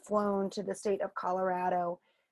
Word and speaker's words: Colorado: As you can probably tell as you're listening, Kitty and I both say Colorado with a strong ah sound. – Right. – I think that's a short A is what I'm learning in the first grade class. Short sure. A, like cat, at, cat.